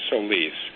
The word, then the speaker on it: Solis: I'm comfortable basically talking about how the secretary, and by the way, the correct pronunciation of her last name is Solis.